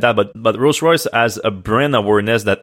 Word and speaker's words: Rolls Royce: The British automaker, Rolls Royce.